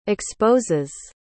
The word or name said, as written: exposes